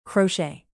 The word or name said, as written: crochet